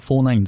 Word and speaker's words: 9999: あの、 9999 って書いて、 49s って読むんですけど、あの、これ、変な名前なんだけど、金の品質表示を表してるんですよ。